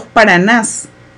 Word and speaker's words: Paranás: Entonces, podríamos decir, es un error decir el nombre correcto del grupo son los Paranás. – ¿Cómo? Perdón, no lo entendí. – El nombre correcto del grupo entonces vendría a ser los Paranás, con el acento en la...